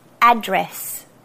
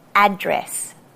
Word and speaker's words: address: Another one is address. Not address. Address. And then everybody's favorite, aluminium.